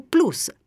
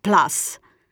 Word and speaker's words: plus: Sono invece da evitare le pronunce anglicizzate di iter, che diventa iter, junior, che diventa junior, o plus, pronunciato plus.